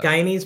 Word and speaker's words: Guyanese: Guyanese pepper pot.